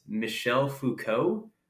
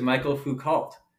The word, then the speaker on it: Michel Foucault: And I thought, I think it's Michel Foucault. I thought he was Michael Foucault.